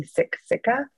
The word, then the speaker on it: Siksika: Siksika, it's spelled S-I-K-S-I-K-A. Yes. And so if you're reading it with an English kind of mindset, then you're going to see Siksika, but yep. it's actually pronounced Siksika. So that is actually a more of a soft, almost a G okay.